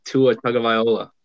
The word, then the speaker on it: Tua Tagovailoa: All right, number five, Dolphins select Tua Tagovailoa.